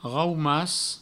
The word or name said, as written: raumas